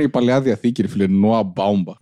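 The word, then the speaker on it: Baumbach: Δεν λέγεται Μπόμπακ. Εγώ πιστεύω ότι προφέρεται Μπάουμπαχ. Το Μπάουμπακ μου πάει λίγο αλαμπάλακ, δεκάρι, παλιό, παλαιά κοπή.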